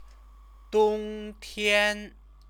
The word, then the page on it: dongtian: chinese